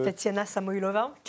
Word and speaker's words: Tatiana Samoylova: Il y a la très belle, euh, comment, comment on le prononce Tatiana Samoylova.